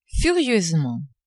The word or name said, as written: furieusement